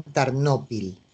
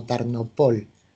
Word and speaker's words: Tarnopol: Así es, ahora es una ciudad importante, Tarnopol, ahora en Ucrania, por lo tanto la llaman Tarnopil, pero los judíos se refieren a ella incluso como Tarnopol, con el acento en la última sílaba.